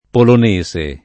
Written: polonese

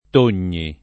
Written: Togni

